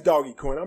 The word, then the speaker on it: Dogecoin: it is Dogecoin.